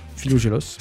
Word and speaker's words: Philogélos: Je ne sais pas si je le dis bien. Philogélos.